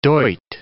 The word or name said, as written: doit